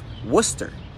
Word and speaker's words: Worcester: It's pronounced Worcester. That's right, has a couple of silent letters for no reason.